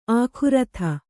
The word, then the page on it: ಆಕೃತಿ: ākřti